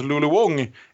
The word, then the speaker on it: Wang: Och eh, Ja, och denna Lulu Wang som det stavas, men som Aron precis har informerat mig snarare uttalas Lulu Wong, är ju en relativt ny regissör.